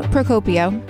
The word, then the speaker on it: Procopio: Am I saying that right? – Procopio. – Procopio.